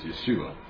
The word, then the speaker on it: Yeshua: So, I don't know how are they coming up with this, because by my understanding, the Hebrew pronunciation of Jesus is Yeshua.